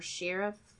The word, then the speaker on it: Sharif: I believe you pronounced this Sharif or Sheriff or S-H-A-R-I-F. – Sharif, it looks like.